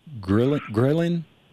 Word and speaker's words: ghrelin: And then it said in one study, men had a lower, do you pronounce that ghrelin? G-H-R-E-L-I-N? – Oh, yeah.